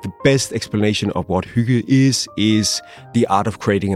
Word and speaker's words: hygge: One theory involves a word that is spelled H-Y-G-G-E. – It's pronounced hygge.